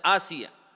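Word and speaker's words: Asiya (آسية): Unfortunately, amongst us, especially Asian people, we don't differentiate between the pronunciation of Ayn and Hamza. It's Asiya.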